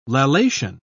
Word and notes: lallation: British English

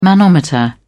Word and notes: manometer: British English

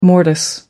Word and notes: mortise: American English

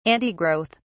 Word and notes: antigrowth: British English

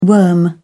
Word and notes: worm: British English

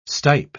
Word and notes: stipe: British English

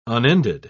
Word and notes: unended: British English

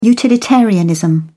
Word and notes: utilitarianism: British English

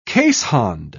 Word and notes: keeshond: British English

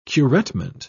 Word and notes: curettement: British English

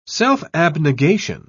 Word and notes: self-abnegation: British English

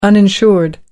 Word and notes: uninsured: British English

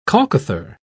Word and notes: colcothar: British English

